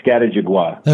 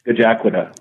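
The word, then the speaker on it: Scajaquada: We've heard that mangled, I don't know how many, like Skatajigwa. – Okay. So now our listeners have the inside track when they come to CNU. Say that one more time then, the right way.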